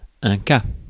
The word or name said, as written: case